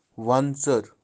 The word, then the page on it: wantser: wantsar